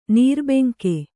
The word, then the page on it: ನಿರ್ಭಂಗ: Kannada